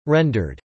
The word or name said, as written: rendered